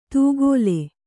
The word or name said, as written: ತೂಗೋಲೆ